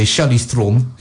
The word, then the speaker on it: Charlize Theron: The story revolves around a teenage fiction author played by Charlize Theron, or because she's South African, you have to say Charlize Theron, because that's how she pronounces it.